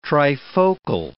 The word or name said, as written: trifocal